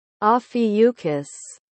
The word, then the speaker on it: Ophiuchus: we'll insert it, in a recording. Correct pronunciation. – Ophiuchus.